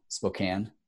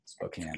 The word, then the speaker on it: Spokane: I just said it wrong. Spokane? – Yes. – Spokane. – It's good.